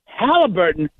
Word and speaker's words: Halliburton: Even Halliburton, am I pronouncing it correctly now? – Yeah.